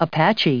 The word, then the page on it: Apache: American English